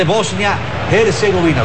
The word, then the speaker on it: Bosnia-Herzegovina: El 2-0 hundió por el momento las aspiraciones de Bosnia-Herzegovina.